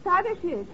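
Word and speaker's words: Tadeusz: In Poland, we say his name Tadashish Kosciuszko. But in America, Tadashish Kosciuszko is fine. – Please. – Thank you, young lady. Tadashish Kosciuszko. How's that? – Very good. You say it like you're going to sneeze, and you got it.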